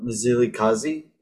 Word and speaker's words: Mzilikazi: So in the relevance of what we're going to talk about next, so you just talked about like, sorry if I butchered the name, but Mzilikazi. – No, it's good. You got it.